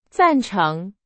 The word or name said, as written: 赞成